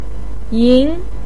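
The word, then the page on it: yin: tone 2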